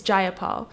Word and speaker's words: Jayapal: It's Jayapal.